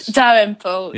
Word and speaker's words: Dalrymple: And there's a portrait of a woman called Grace, I'm not going to say her name right, Dalrymple Elliot? – Dalrymple.